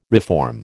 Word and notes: reform: American English

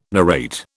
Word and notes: narrate: British English

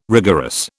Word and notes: rigorous: British English

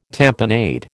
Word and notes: tamponade: American English

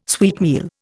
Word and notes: sweetmeal: Indian

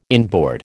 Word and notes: inboard: American English

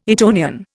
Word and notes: Etonian: Indian

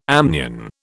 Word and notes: amnion: British English

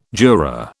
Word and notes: juror: British English

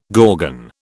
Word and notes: gorgon: British English

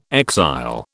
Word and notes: exile: British English

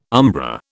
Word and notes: umbra: British English

